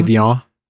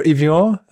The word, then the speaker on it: Evian: Evian.